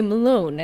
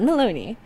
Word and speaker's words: Malone: But now we know it's actually Malone and not Maloney. So I can mm-hmm. finally say his name properly now.